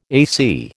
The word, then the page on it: AC: American English